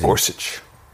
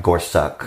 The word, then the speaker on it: Gorsuch: Gorsuch. – All right, Gorsuch.